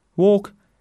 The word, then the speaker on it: walk: Walk and talk. So our words for today were people, little, call, well, fill, and exceptions where the L is not pronounced would be walk and talk.